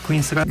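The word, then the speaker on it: Queensrÿche: I na końcu tu mieliśmy problem z wymową tego zespołu, Queen's Rap. – No bo tu się dwie szkoły spierają, jedna szkoła Witkowa, druga strona... – Nawet moja, bo ja kiedyś wymawiałem Queen's Rap.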